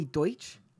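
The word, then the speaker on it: Deutch: Adaptation of Rachel Mead's novel starring Zoe Deutsch. Apparently is how you pronounce it.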